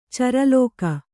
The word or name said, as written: ಚರಲೋಕ